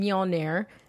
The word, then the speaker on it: Mjolnir: Our Thanos head is on Mjolnir.